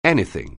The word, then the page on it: anything: anglais